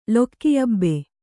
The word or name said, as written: ಲೊಕ್ಕಿಯಬೆ